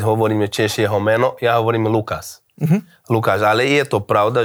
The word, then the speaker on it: Lucas: tak tam sa v jednej časti sa to vyslovuje, že Lukáš takým, nie že š, ale také nejaké zaoblené. Ale že všade inde sa hovorí Lukáš.